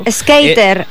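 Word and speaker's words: Sk8er: Skater. Perquè pensa que el número 8 en anglès és 8, skate. – Ah, clar, clar, clar, és un joc de paraules, eh? – Sí. – Doncs Complicated i Skater Boy, transformant Lavigne en la reina del pop-punk i en una de les artistes joves més venudes de la història.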